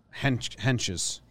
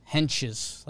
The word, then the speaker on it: Hentges: I mean, I'm not I think it is a J. – Henches. – Henches, like henchmen.